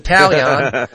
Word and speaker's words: Taillon: I like when people pronounce his name because they're switching the I is what they're doing. They're moving it over like it's T A L L I O N, and they're like Jameson Taillon.